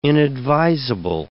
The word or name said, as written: inadvisable